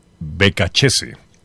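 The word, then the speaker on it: Beccacece: El técnico, el apellido creo que se pronuncia es Becachese. Becachese. Becachese. Becachese. Se escribe Becacese, pero es un apellido italiano, Becachese.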